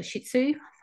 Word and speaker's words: shih tzu: I think it's a shih tzu. Is that how you pronounce that? – Yep.